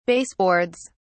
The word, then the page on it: baseboards: British English